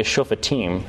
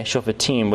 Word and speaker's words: Shofatim: So in terms of the title for the book of Judges, the Hebrew term, as you would pronounce it, is Shofatim. Okay, Shofatim, which is judges.